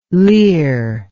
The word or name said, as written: lehr